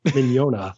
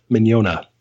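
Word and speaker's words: Mignogna: Mignona, like mañana. Yeah, Mignona.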